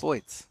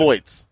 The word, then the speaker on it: Voigts: Say your last name for me. Voigts? – Voigts. – Voigts. Voigts. – Yep. Voigts. – Voigts. – Like John Voigt, but Voigts.